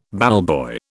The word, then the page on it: ballboy: British English